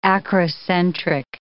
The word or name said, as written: acrocentric